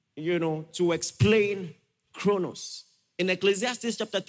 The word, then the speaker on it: chronos: The first, chronos, and the second, kairos. Chronos is spelled C-H-R-O-N-O-S. Kairos is spelled K-A-I-R-O-S.